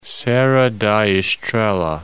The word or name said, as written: Serra da Estrela